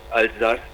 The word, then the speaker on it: Alsace: Well, it's Elsass if they say it in German. It's Alsace if you say it in French.